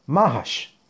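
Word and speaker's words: Mahash (מהש): Y se debe meditar el santo nombre de Mem Hei Shin, que se pronuncia Mahash.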